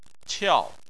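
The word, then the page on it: qiao: tone 4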